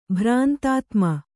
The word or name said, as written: ಭ್ರಾಂತಾತ್ಮ